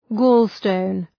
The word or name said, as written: gallstone